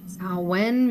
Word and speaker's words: Samhain: Auch warum wir heute in der Schulmedizin das vergessen haben, ist ein Thema und ja, jetzt fängt es ja so an, Samhain, Samhain, wie es ja eigentlich ausgesprochen wird, ne?